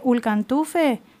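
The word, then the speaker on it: ülkantufe: Que por ahí después se comparte de otras maneras, hay cantores, ¿cómo se dice? ¿Ulcantufe? ¿Puede ser? – Sí, al cantor. Muy bien dicho, muy bien, muy bien dicho.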